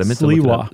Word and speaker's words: Sliwa: How do you pronounce that? – Sliwa. – Sliwa in February 1979.